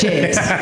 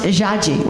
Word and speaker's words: Jade: My real name is Jade. In Portuguese, it's pronounced Jaji, which actually means penis in Chinese.